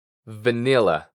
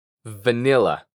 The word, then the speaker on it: vanilla: Y la doble L la vamos a pronunciar como si fuera solamente una. La. La. Nila. Vanilla. Vanilla. Muy bien hecho.